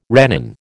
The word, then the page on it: rennin: American English